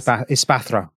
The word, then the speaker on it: Espathra: Okay, so Tulip has Ferrigaraff, Gardevoir, Espartha. How do we say this? – Espathra. – Espathra, and then a Florges.